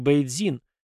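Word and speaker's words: Бэйцзин (北京): Кстати, название этого города по-русски звучит как Бэйцзин, что значит «северная столица», но русские называют его Пекином.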